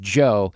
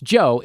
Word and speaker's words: Djo: So there's a bumper music piece that I played, and one of my favorite songs right now is by an artist that goes by the name of Joe, but it's spelled D-J-O, all right? – Yeah. – So but it's Joe, and this song is called Basic Being Basic.